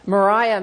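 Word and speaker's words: Maria: Mariah Mitchell, and that is how she pronounced her name, was the first female professor of astronomy in the U.S.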